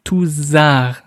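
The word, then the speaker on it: Tousart: Weil es ist nämlich nicht Lucas Toussaint, so wie es sehr viele sagen, sondern Toussaint, ja, also ein bisschen wie ein Z weich ausgesprochen.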